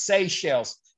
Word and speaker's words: Seychelles: I think it's pronounced Seychelles. Seychelles, like Seychelles.